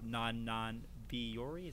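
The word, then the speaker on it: Non Non Biyori: Today we're going to be talking about Non-Non-Biori, is that how you say it? – Yep. That's it.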